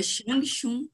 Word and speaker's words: Changchun: Eu quero, primeiro que você pronuncie o nome do clube onde você treina, que todo mundo tem dificuldade em pronunciar como é que se fala. – Certo, o nome do clube é Shangchun Hong.